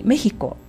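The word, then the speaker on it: México: で、それから、この国メヒコ、これもメヒコで目が強く発音されるので E の上にアクセントがつきます。なんですけれども、私今までにメヒコという単語がテキストブログの中に 2 箇所出てきてまして、どちらもつけ落としてたんですね。で、慌てて直したんですけれども、以前にねメヒコっていう単語の綴りを確認された方、間違ってました、申し訳ありません。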